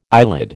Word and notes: eyelid: American English

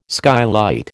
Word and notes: skylight: American English

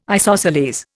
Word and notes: isosceles: Indian

